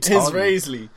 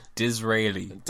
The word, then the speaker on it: Disraeli: Von John Raisel. – Who are you talking? – Disraeli. – Disraeli.